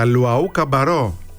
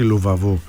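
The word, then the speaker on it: Luwawu-Cabarrot: Για Λουαού Καμπαρό, έτσι όπως προφέρεται, και όχι Λουβαβού.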